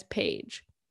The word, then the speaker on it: Page: His last name is simply pronounced as Page.